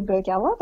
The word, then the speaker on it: Berghella: So it's just a play on my surname, which is Kelly Berghella, but no one seems to be able to pronounce it, so I thought I might make it in a more digestible format.